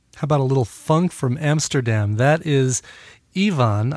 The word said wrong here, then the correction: Iwan: I hope it's pronounced Ivan. I know if it was Welsh, it would be Iwan. It's Iwan with his cover of Money for Nothing from his 2008 album Rhythm Is My Business.